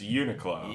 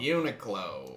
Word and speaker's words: Uniqlo: Apparently on the PA system, it's pronounced Uniqlo. – Uniqlo.